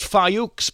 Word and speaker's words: Fuchs: Okay, here's a lady whose surname is pronounced Fayouks, but spelled Fuchs.